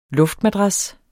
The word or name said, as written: LUFTMADRASSER